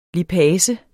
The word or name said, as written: lipase